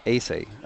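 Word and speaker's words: Èze: It's E-Z-E, Ace, I think they pronounce it.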